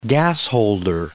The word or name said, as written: gasholder